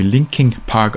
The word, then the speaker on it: Lincoln Park: Und wie zu lesen ist, äh, stammt der Name von einem Park, der Lincoln Park in Santa Monica. Und tatsächlich wird aber dieser Park wohl wie Linking Park ausgesprochen und ist damit eben Namensgeber der Band.